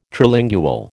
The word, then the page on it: Trilingual: American English